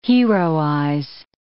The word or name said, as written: heroize